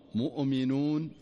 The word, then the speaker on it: مؤمنون: فتجده يقرأها فيقول والمؤمنون، مؤمنون الصحيح، والمؤمنون والمؤمنات والمؤمنون. شوف لاحظ الهمزة، لاحظ الهمزة أنها ساكنة دون قلقلة.